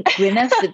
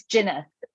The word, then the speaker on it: Gwyneth: It's like Gwyneth, the W, so it's Gwyneth.